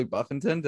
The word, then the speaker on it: Buffington: I honestly didn't know about this guy 15 minutes ago, but Charlie Buffington, did I say that right? – Yes, you did.